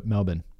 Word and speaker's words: Melbourne: a pronunciation guide for Melbourne. Melbourne, though. M-E-L-B-I-N.